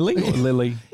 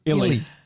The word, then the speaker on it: Illy: And is Lily Illy or Lily? – Don't ever say that again. You just butchered him. – Illy. I-L-L-Y. – Yeah, Illy.